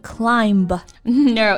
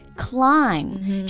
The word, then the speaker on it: climb: 那首先啊，我们来说说这个字母 B 啊，嗯，经常听到有同学会读错，比如说爬山的爬 climb，C L I M B，是不是很多同学会读成 climb？No， it's actually climb 这个字母 b 呢是不发音的，climb。